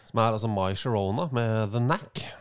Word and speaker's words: Girona: Jeg vet det uttales cirka Girona, men finnes det en kur mot å få denne på hjernen hver gang man titter på tabelltoppen i La Liga? – Hva det du mener?